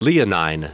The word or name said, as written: leonine